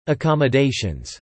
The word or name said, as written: accommodations